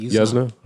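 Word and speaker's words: Yuzna: This one was directed by Brian Usna. Is that right? – Usna? That's right, Usna.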